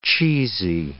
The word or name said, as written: cheesy